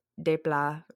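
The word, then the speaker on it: Alexandre Desplat: For those of you who don't know, Alexander Des- Desplat, or however the fuck you pronounce his name, because he's French, uh, if you want to be super American, just call him Desplat.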